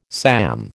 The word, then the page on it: SAM: American English